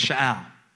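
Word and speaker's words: sha'al (שָׁאַל): This word require, and I wrote it down so I won't forget it, comes from a Hebrew word, okay, sha'al. I don't say it right, but it's sha'al. S-H-A, and the accent is on the first syllable, A-L. So it's sha'al, actually, sha'al.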